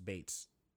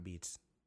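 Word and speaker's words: Beetz: You have Zachary Quinto, Mahershala Ali, John Hamm, Ezra Miller, Lauren Cohan, who was in Walking Dead 2, Zazie Bates, and it's pronounced Bates, not Beats, as I always thought.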